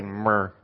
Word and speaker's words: myrrh: Let's spell it M-Y-R-R-H. Right? Like, come on. That's myrrh right there.